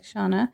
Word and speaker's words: Shauna: Well, most of my stuff's just under my name, like Shauna. – Can you spell it? – Yeah, S-H-A-U-N-A. And then my last name's Arnott, A-R-N-O-T-T.